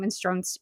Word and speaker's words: minestrone: minestrone. Is it minestrone? – I yes. always say it wrong.